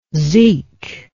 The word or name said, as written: zeke